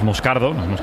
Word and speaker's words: Moscardo: Como bien ha pronunciado Unai, es Moscardo, es brasileño, sí.